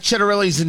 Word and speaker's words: Ciattarelli: Until yesterday, nobody knew how to pronounce uh, uh, Jack Ciattarelli's name. C I A T T A R E L L I, Jack Ciattarelli.